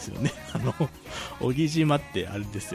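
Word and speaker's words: 男木島: 荻島男に木と書いて荻島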